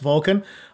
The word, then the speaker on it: VLCN: I actually only learned recently that it's pronounced Vulcan. I always just pronounce VLCN.